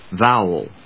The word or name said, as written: vowel